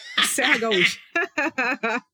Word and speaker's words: Serra Gaúcha: So I'm That's how to... you pronounce it. Serra Gaúcha. oh my god. – Butcher no, away, Beautifully. That is correct.